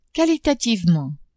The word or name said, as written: qualitativement